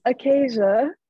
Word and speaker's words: Akassia: it is Akassia.